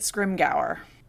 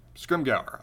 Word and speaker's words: Scrimgeour: I'm pronouncing it Scrimgeour. – Scrimgeour.